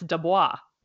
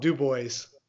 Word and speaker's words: Dubois: I have a friend who has that last name, and he pronounces it with a French accent, and it's Dubois. – I'd have to get into that with them, because that's a whole thing too. But in Pennsylvania, it's called Dubois.